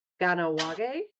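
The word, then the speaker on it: Kahnawake: Did a little bit of research between the Ganawage, did I say that right? Reserve? – That's right.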